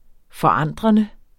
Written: forandrende

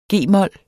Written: g-mol

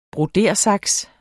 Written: broderesaks

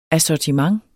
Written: ASSORTIMENTER

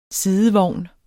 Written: sidevogn

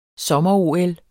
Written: sommer-OL

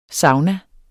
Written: sauna

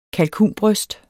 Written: kalkunbryst

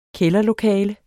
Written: kælderlokale

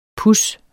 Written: puds

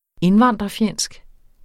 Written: indvandrerfjendsk

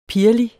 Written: pirrelig

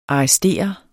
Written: arrestere

